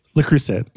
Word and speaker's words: Le Creuset: They have the Le Creuset. Is that how you pronounce that even? Le Creuset? – Le Creuset.